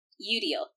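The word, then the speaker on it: Eudial: So then the fight breaks out and no talisman, and they defeat the Daemon, and Uriel's, like, bounces. – Udiel. You keep calling her Uriel, and it's Udiel.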